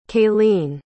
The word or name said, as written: kalene